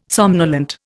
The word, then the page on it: somnolent: Indian